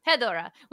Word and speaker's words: Hedorah: Hedorah, which means slime or mud monster, right?